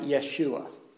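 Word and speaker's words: Yeshua (ישוע): The way it would be pronounced would be something like Yeshua.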